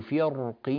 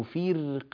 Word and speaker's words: فرق: يجوز في كلمة فرق تفخيم الراء كما نطقته الآن، فكان كل فرق كالطود العظيم، أو ترقيق الراء فكان كل فرق كالطود العظيم. فلذلك يجوز التفخيم ويجوز الترقيق، كله صحيح جائز عند القراء.